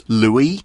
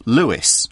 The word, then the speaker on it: Louis: Now, I gave that name the French pronunciation on purpose, Louis, instead of Louis.